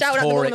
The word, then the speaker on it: Horwich: We're in Horwich. – Is there any prostitute street on here? – They're all prostitutes here. Everyone is a prostitute. – It's pronounced Horwich.